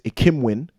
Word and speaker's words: Ikhinmwin: So I think even the thing you brought up, Lamont, about the name, like my surname is Ikhinmwin. That's how we pronounce it in English. But the end, W-I-N, win, you know, we have it on the wall in the office. And from young people just be like, I can win.